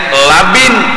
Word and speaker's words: labin: Asyaddu bayadan minal labani, itu laban, labani bacanya laban, bilanya labin bata. Ya Allah. Membarang aja bacaannya aja labin kah laban kah, salah baubah maris baubah makna. Labin bata, laban susu.